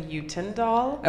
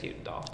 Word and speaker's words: Utendahl: It's a Dutch last name, so there is like a Dutch way to pronounce it, but the American English way to say it is Utendahl.